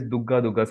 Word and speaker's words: Durga: So Durga Durga, but it is kind of, you know, pronounced as Dugga Dugga.